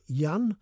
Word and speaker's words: Jan: Now, somebody who signs themselves Yan, J A N, pronounced Y A N, says, Hi, as a 71 year old learning to play the guitar, I'm very much into the music of Eric Bogle.